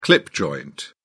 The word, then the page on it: clip joint: British English